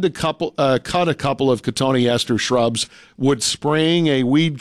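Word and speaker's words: Cotoneaster: Don had to cut a couple of Cotone East Ester shrubs. – Cotone Ester? – Yeah. – Yeah, it's spelled like cotton Easter, but yeah. – Okay. – Cotone Ester.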